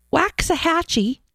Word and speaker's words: Waxahachie: Signed, gender normal in Waxahachie, Texas. Is that it? Did I say that right? – I think, yeah, it's a soft day. Waxahachie.